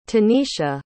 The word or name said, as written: tanesha